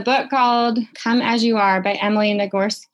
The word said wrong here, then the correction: Nagoski: uh, Nagoski.